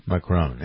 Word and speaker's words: Macron: Macron.